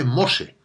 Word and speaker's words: MOSE: Este nombre bíblico, Moisés, que, como sabes significa salvado de las aguas, en italiano se dice Mose y es el acrónimo de Módulo Experimental Electromecánico.